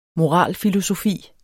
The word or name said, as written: moralfilosofi